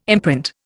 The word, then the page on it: imprint: Indian